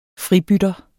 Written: FRIBYTTEREN

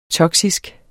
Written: toksisk

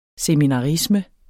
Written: seminarisme

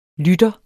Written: lytter

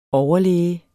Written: overlæge

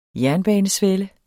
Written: jernbanesvelle

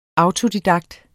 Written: autodidakt

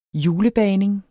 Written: julebagning

